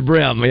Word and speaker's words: bream: Well, bream, B-R-E-A-M? – Brim. – I thought brim was B-R-A-M. – Fish is a brim, yes. – Brim. It's brim.